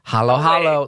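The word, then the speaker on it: halo-halo: Hollow hollow. Let's talk about the hollow hollow. – Hey, oh my God. – Am I pronouncing that correctly? – Yes, you are. Hollow hollow, yes.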